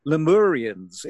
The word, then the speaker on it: Lemurians: Let's just quickly start off by talking about that very strange place of the Lemurians. Is that how you pronounce the name? I've always been curious about that. – Yeah, that sounds fine to me.